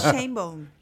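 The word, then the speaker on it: Sheinbaum: ¿Pero cómo se pronuncia? – ¿Shanebaum? – No, no, es Shanebaum. Shanebaum.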